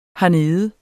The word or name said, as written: hernede